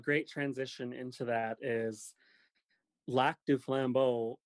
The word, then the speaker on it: Lac du Flambeau: So, all right, so let's back up a little bit, because you said you're from the Lac des Flambeaux Ojibwe Indian Reservation in northern Wisconsin.